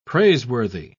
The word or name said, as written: praiseworthy